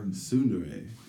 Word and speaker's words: Tsundere: Tsundere? – Is that the T? Is that how you say that? – That's the Japanese pronunciation.